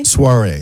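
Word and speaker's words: Soirée: How do you say that? Soiree? – I don't know. – Yeah. – Soiree. – Okay. That's it.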